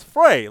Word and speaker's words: Frey: Because every time I've seen it spelled that way, it's Frey, like Walder Frey from Game of Thrones, Mr. Frey from around the corner.